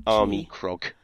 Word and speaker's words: Armikrog: K-R-O-G. – Yeah, wait, what's that? – A-R-M-I-K-R-O-G. – O-G. – Armacrog.